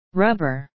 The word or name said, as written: rubber